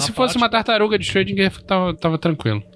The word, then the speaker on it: Schrödinger: Na faculdade a gente chamava ele de Schrödinger. – Schrödinger. – Tá válido.